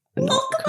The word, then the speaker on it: Lachlan: I remember listening to a podcast from America and they didn't know how to say Lachlan. They were like, and there's this name. I hope I'm pronouncing it correctly. It was Lachlan.